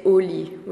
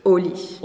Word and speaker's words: au lit: We would say au lit, which is, you know, in bed. – So the pronunciation now is? – Au lit. Au lit. Instead of en lit.